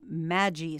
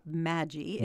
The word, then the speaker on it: Maggi: You know, I always wondered what this Maggi sauce is, which, by the way, is not pronounced Maggi. It's Maggi.